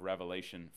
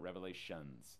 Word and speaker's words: Revelation: He was exiled to the island of Patmos under house arrest, and it's there that he records the book of Revelation for us. And by the way, it is pronounced Revelation, not Revelations. It's not plural.